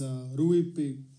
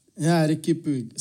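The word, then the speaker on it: Riqui Puig: That is, first is Trincao, and second is Rui Puig. Like, I don't know how you pronounce it. Ricky Puig.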